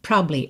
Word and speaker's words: probably: Isso só funciona se você estiver falando rápido, porque se for lento, fica estranho. Probably. Fica na cara que você comeu uma sílaba e que ela tá faltando, né?